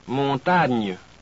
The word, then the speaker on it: montagne: Next, the letters G and N are pronounced somewhat like our NY in canyon or NI in senior. Listen to these. Montagne.